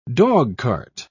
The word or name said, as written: dogcart